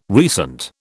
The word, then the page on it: recent: British English